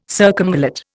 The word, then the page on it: circumvallate: Indian